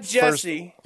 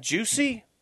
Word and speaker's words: Jussie: It's not Jesse. Juicy.